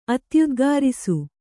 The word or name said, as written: ಅತ್ಯುದ್ಗಾರಿಸು